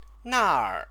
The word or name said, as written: naer